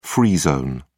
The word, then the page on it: free zone: British English